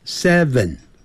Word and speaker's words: seven: It 二三四 it 短的元音 six six，很多人会念成 sex， sex 是性，性别那个性，好 sex 那是 s e x，所以六啊要念成 six six seven 七 s e v e n seven ven 那个 v。要把上牙齿咬的下嘴唇发 v 的音 seven seven，八 e i g h t，那个好念 g h 不发音，念成 eight eight eight，九呢，因为是 n i n e，所以前面有个 n 就要念成 n。